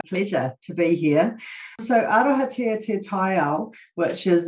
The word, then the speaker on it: Arohatia te Taiao: And Arohatia Te Taiho, did I pronounce that right? – Pretty close, yeah. – Pretty close.